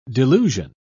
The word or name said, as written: delusion